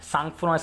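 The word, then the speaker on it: sangfroid: सांगफुरा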